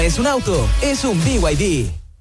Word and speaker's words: BYD: ¿Los VIB? – ¿VIB? ¿No es BYD? – Nada que ver. Se escribe BYD, pero se dice BYD.